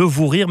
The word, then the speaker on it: Vous Rire: On va parler maintenant du Vous Rire Festival qui a changé de nom mais pas vraiment de prononciation puisque ça s'appelle toujours le Vous Rire mais ça s'écrit V-O-U-S maintenant.